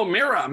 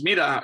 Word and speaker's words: Mira: Oh, Mira. Mira is great. It's Mira in Spanish.